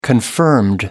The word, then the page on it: confirmed: American English